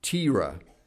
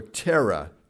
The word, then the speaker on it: Terah: These verses, these last verses of the chapter, give a brief account of Abraham's father, Terah, or Terah, depending on how you want to pronounce it.